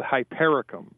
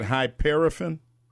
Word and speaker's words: Hypericum: The medicine that she wants is called Hypericum. – You said Hyperifen? – Yeah, I'll spell it for you.